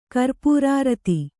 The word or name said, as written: ಕರ್ಪೂರಾರತಿ